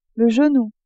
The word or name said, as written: genou